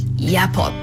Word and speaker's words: JAPO: しかし実を言いますと JAPO のジャポと書いてヤポと読むというね、ややこしいんですけれども、ただこれちょっと説明しますと、あのヤポ JAPO、これはアイヌの言葉で陸の子供っていう意味なんですね、うん。